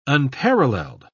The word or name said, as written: unparalleled